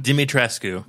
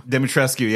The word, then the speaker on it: Dimitrescu: Or how do you say that last name? – Dimitrescu. – Dimitrescu, yeah.